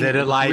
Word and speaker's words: vitiligo: if you got rid of this vitiglio or whatever you pronounce it on the thing." – Vitiligo. Really nice.